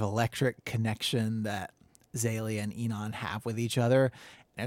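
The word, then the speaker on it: Inan: This guy is it's Enon. – Okay. – Is that his name? – Yeah. – Or that's a pronunciation that you got? – Yeah. Mm-hmm.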